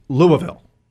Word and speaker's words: Louisville: Although somebody told me the other day I'm supposed to pronounce it Louisville. – Louisville. It's Louisville. – It's like Tom Brokaw. Louisville.